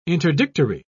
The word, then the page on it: interdictory: British English